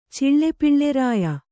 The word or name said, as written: ಚಿಳ್ಳೆಪಿಳ್ಳೆರಾಯ